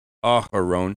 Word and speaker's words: Aharon: And Aharon, it actually has a hay in front of it. Aharon is how you say Aaron.